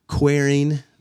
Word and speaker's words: Quareing: And before we just kind of get going on the topic, which is frontiers in womanism, Quareing the approach, did I say that right? – Mm-hmm. There, we'd just love to hear more about your backstory, if that's okay, and kind of how you became an academic.